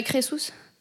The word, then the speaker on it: Crésus: C'est pas Crésus, hein, c'est Crésus. Oui. Oui. Comment ça s'écrit? – C-R-E-S-U-S.